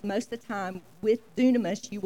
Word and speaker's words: dunamis: And this word is pronounced in the Greek dunamis, and it's a very important word.